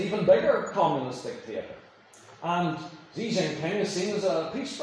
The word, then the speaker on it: Xi Jinping: We have, for instance, Xi Jinping. That is the right pronunciation of the Chinese premier, prime minister, whatever you call him.